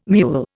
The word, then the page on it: mewl: Indian